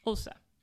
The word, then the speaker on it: Xhosa: Abby-yo-yo is a song in a language called Xhosa. You pronounce the XH with a clicking sound.